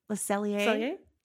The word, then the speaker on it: Le Cellier: We have Le Cellier. Is that how you say it? – Le Cellier. Le Cellier in Canada.